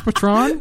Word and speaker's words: Patreon: Is that not pronounced Patreon? – Patreon.